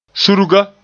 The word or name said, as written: surga